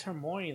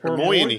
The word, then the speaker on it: Hermione: He bangs Hermione, the hottest chick. – Hermione. – Hermione. – Hermione.